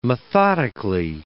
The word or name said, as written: methodically